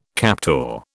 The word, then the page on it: captor: British English